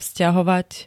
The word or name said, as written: vzťahovať